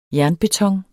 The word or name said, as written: jernbeton